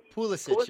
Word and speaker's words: Pulisic: Pulisic? I don't know how to pronounce his last name. – Pulisic, yes.